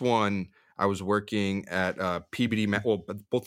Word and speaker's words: Peabody: That's the town, but it's spelled Peabody. – Okay. – P-E-A-B-O-D-Y, but in Mass, you pronounce it PBD.